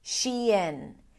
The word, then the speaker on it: Shein: Shein, which is basically a combination of two words, she and in.